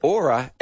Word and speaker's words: Oura: Written, yeah, but it's spelled O-U-R-A, but the wonderful people who have written the ad have said Aura as in flora, just in case I don't know how to pronounce it.